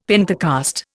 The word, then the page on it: Pentecost: Indian